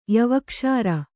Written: ಯವಕ್ಷಾರ

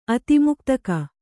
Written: ಅತಿಮುಕ್ತಕ

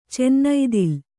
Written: ಚನ್ನೈದಿಲು

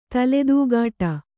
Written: ತಲೆದೂಗಾಟ

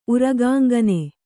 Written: ಉರಗಾಂಗನೆ

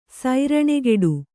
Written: ಸರಣಿಗೆಡು

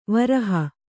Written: ವಿರಹ

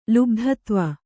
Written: ಲುಬ್ಧತ್ವ

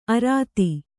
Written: ಅರದ

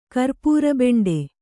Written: ಕರ್ಪೂರಬೆಂಡೆ

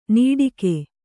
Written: ನೀಡಿಕೆ

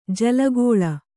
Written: ಜೋಲುಗಾಲು

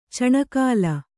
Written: ಚಿಣಿಕೋಲು

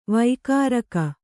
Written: ವಾಗುರಿಕ

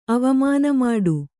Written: ಅವಮಾನಮಾಡು

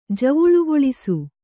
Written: ಜವುಳುಗೊಳಿಸು

